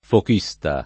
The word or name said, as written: fochista